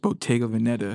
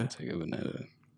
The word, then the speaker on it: Bottega Veneta: Bottega Veneta. Bottega Veneta.